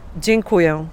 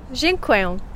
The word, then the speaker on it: Dziękuję: I think we've been saying it a little bit wrong. I've been saying dziękuję. Is that wrong? – Okay, but I can perfectly understand you. Dziękuję is good. – Okay, so how do you say it again? – Dziękuję. – Dziękuję. – Aha, better. Whoa, that's better.